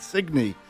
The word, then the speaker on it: Signe: It was it's a Danish name, and they pronounce it Signe.